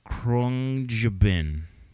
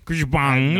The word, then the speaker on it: Khruangbin: Krungjubin. – Give us a spelling. – Try and pronounce this, okay? K-H-R-U-A-N-G-B-I-N. – Krishbang. – Krishbang. No. All right, so that band, really cool band, instrumental band.